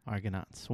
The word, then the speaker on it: Argonauts: Argonauts, did I say that right? – Yes, you did. – Argonauts.